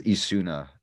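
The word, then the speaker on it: Esuna: Yeah, she uses Isuna. That's, I guess, how we pronounce that, or how I'm pronouncing it. It's like sauna.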